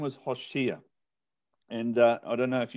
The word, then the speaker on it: Hosea: It says Hosea, and this is how you say Hosea. It's a ha, and this is a vav with a dot on the top for all my Hebrew students. That translates into an o.